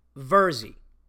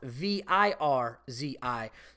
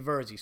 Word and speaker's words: Virzi: Because I know what it's like when people fuck up my name, because my name is pronounced Verzi like Jersey with an E, but my name is spelt V I R Z I. So some people think it's Virzi and they fuck it up, but it's actually Verzi.